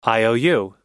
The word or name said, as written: iou